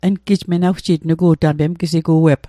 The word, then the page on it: menawjit: Mi'gmaq